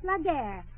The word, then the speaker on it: la guerre: But you know, c'est la guerre. – What? – C'est la guerre. – All right, la guerre.